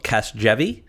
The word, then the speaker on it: Casgevy: Well, let's we'll get the pronunciation out of the way. This is a medicine that's called Casgevy.